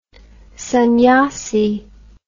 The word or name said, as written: sanyasi